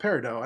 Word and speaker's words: Peridot: I told my sister, I asked my sister, how do you pronounce Peridot? And she's like, Peridot. And I'm like, why? And she's like, it's French.